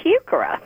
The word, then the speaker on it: heuchera: It's heuchera. – And heuchera, even though it's a weird name to pronounce, H-E-U-C-H-E-R-A, heuchera.